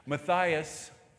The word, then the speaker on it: Matthias: Matthias was replaced.